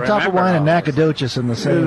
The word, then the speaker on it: Topperwein: The interesting thing about it was everybody says Topper Wine. Okay. Even back in the old days, they would say Topper Wine. And so when they would spell it, they put it T-O-P-P-E-R-W-E-I-N.